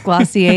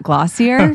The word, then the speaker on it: Glossier: Now, is it for sure pronounced Glossier and not Glossier? – Glossier. – You're putting kind of a French twist on it.